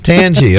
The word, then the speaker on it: Tangi: let's see, from Port St. Lucie, and I believe that would be Tangi. That look like Tangi? – Yes, I heard Dave say that. – It is Tangi. Okay, I just want to make sure I was pronouncing it right.